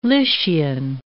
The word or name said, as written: Lycian